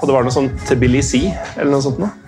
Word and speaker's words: Tbilisi: Altså, for min del så det skrives jo Tbilisi, ja. – Men jeg sier det nesten alltid Tbilisi, og det jo definitivt feil.